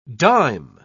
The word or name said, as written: dime